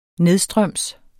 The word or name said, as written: nedstrøms